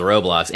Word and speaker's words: Roblox: And I won't make too much fun of the fact that I think you just pronounced Roblox with a French accent or something. That was interesting. But anyway, I'm not so hip as to be on Roblox, but at least I know it's pronounced Roblox.